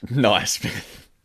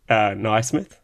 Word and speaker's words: Naismith: Naismith. – Yeah, that's right. Naismith. It's the right way to pronounce it. Don't let DOS prove otherwise.